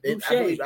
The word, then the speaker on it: Boucher: Boucher. I butchered that last name. – Yeah, Boucher.